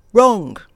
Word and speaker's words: wrong: そしてその後ろの単語、悪い、間違ったといった意味の W 読みませんが、 R で始まります。 – Wrong, wrong. – 私たちの日本語にはこの発音はございません。ですので意識をしないと伝わるような R の発音できないんですね。